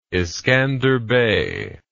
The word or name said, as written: iskander bey